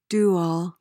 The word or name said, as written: do-all